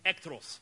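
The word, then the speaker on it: echthros: That word in the Greek is ekthros. It's spelled E-C-H-T-H-R-O-S. E-C-H-T-H-R-O-S.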